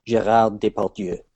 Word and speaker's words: Gérard Depardieu: Yeah, Gérard Depardieu.